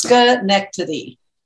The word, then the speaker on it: Schenectady: Schenectady. But it's pronounced Schenectady.